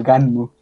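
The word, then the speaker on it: Gunnm: Los japoneses lo pronuncian como Ganmu, entonces está muy chistoso y fue escrito.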